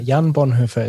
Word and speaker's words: Jan Bonhoeffer: Hello, Dr. Jan Bonhoeffer. Did I say your name right? – Thank you, Christina. Jan Bonhoeffer is my name.